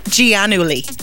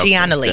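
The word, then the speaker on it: Giannulli: They say that Lori Loughlin and her husband, however you say his name, how do you say it? – Gianulli. – Yep. Gianulli.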